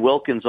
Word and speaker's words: Wilkins: Yeah, it's just my name, at Reed Wilkins on Twitter, R-E-I-D-W-I-L-K-I-N-S.